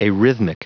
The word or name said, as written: arrhythmic